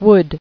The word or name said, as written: Would